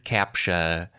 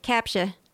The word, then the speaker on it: CAPTCHA: The word CAPTCHA, and I don't really pronounce the T in it when I say it, because I think that would be unpleasant, but it's spelled...